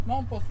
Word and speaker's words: Mompox: En su escritura Malibú terminaba en J, Mompox final, J final. Los españoles tenían dificultad para pronunciar la J y ellos se acomodaban con la X, como sucedía en México.